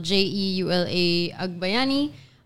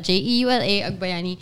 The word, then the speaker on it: Jeula Agbayani: Sa Starbucks, naging Julia, Yuela, whatever. I've been, every permutation of Jeula, it's been out there. I'm saying this is the correct way to say my name and spell it.